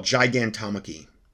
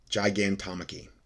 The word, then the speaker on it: Gigantomachy: it's called Gigantomachy. Gigantomachy. That was the what the that's the exact um, way to pronounce it.